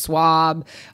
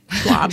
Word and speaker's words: Schwab: Just find one, such as Vanguard or Fidelity, Charles Schwab, all the... Did I even say that right? – Schwab.